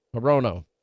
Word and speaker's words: Hirono: Morano? Did I say that right? Hirono. Senator Warnock in Georgia.